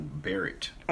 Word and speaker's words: Barrett: Amy Coney, how you say it? Burnett? – It's Amy Coney, Conal Barrett.